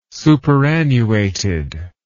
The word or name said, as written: superannuated